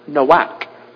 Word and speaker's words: Noach: All right, so Noah in the Hebrew is pronounced Noach, N-O-A-C-H, which means rest.